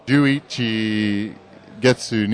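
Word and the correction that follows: Juichi gatsu (十一月): ni. No, Juichi gatsu ni Tokyo ni.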